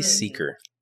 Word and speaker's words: Sieker: Sieker. – Okay. Lacey Sieker.